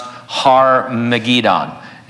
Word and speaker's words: Har Megiddon: Armageddon is derived from the original Hebrew word pronounced Har Megiddon, and so it has to do with this valley of Megiddo.